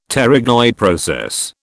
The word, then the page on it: pterygoid process: British English